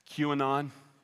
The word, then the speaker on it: QAnon: This is not QAnon. Is that how you say it? QAnon. Yeah, this is, not be, this is not being done in clandestine meetings behind closed doors.